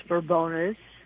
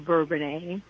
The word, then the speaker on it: Bourbonnais: I do believe the pronunciation of whether it's Bourbonus or Bourbonnais depends on when you learn to spell it.